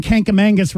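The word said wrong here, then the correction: Kancamagus: There's no N in Kankamangus. It's Kankamagus.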